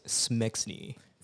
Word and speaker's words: smxne: Follow her, not like she needs more followers, is S-M-X-N-E. So, how do you pronounce that? – I pronounce it as Smexney.